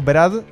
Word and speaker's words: Brad: Brad Meldow. Meldow se escribe. – Ah, yo tenía Brad Meldow y dije, bueno, está. – Brad. – Brad, está. Como Brad Pitt, pero exacto.